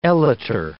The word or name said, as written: elater